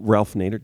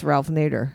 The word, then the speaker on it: Ralph Nader: W- Ralph Nader. Did I s- missay his name? – No, I'm, oh, okay. I'm saying it's Ralph Nader.